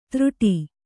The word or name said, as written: ತೃಟಿ